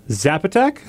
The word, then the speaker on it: Zapotec: Zapotec? – Yeah. – Did I get that right? – Yeah, good job.